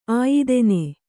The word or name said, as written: ಆಯಿದೆನೆ